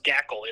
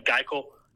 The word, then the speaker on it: Gaeckle: It is pronounced Gackle. It is spelled G-A-E-C-K-L-E. – Like I've been saying Geikle. I've been saying Geikle for like 6 months, and then I was corrected the other day. It is Gackle.